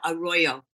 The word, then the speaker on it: Arroyo: So the native people who lived here long before we did, they had sacred places like the Devil's Gate, where the, I believe it's pronounced Arroyo, A-R-R-O-Y-O. – Arroyo. It's Arroyo.